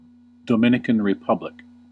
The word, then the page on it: Dominican Republic: /dɒmˌɪn.ɪ.kən ɹɪˈpʌb.lɪk/; /doʊˌmɪn.ɪ.kən ɹəˈpʌb.lɪk/